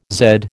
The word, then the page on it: zed: American English